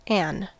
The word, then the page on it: an: American English; stressed